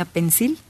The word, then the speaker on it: Pensil: Oye, yo pensaba antes que, que la pronunciaban mal y pensaba que era una palabra en inglés. Hasta que después ya alguien nos dijo, y es la colonia Pensil.